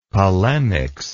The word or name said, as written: polemics